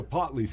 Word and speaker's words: Potli: Is it pronounced Potli? – Yeah, so Potli.